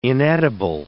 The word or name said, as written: inedible